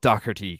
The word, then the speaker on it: Docherty: This guy is actually is called Docherty because it's spelled D O C H.